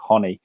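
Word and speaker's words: Ronny: It looks like Ronnie, but I think it's pronounced Honey, the Brazilian, from what I've heard of online.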